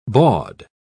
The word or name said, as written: bawd